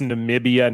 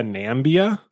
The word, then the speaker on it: Namibia: Is the joke that that Trump pronounced Namibia Nambia? – Yes, that's okay.